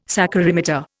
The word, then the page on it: saccharimeter: Indian